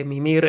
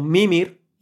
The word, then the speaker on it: Mimir: Pues a veces pronuncian su nombre como Mimir y otras Mimir. La verdad, y los mismos personajes lo pronuncian Mimir. Bueno, es que no se acaban de aclarar, no tendrían una indicación clara. Cory Barlog en las entrevistas le llama Mimir.